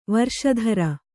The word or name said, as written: ವಾರಿಶತ್ರ